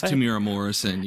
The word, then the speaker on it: Temuera Morrison: The big reveal, if you know what you're looking at, is that I'm always bad at pronouncing his name, but Temuera Morrison, the guy who played Jango Fett. – I think it's Temuera Morrison. Yeah.